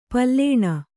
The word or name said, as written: ಪಾಲುಣ